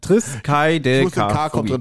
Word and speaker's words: Triskaidekaphobie: Triskaidekaphobie, genau.